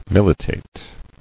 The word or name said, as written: Militated